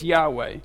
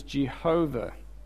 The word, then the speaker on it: Yahweh: And then there is a name of God which is spelt, really the only thing we know about it is this Y-H-V-A or W-A, or depending on your pronunciation. And it's Yahweh. We think it's Yahweh. But when they first started translating Bibles, I think it was an American Standard Version, they said, oh, actually it's pronounced as Jehovah.